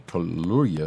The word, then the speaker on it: Kaluuya: Every, but also, I wonder if this spinoff show is going to have Daniel Kaluuya, who was also Kaluri Kaluuya, excuse me, I'm butchering his name, but who also played her husband in the the the MCU uh, in in Black Panther.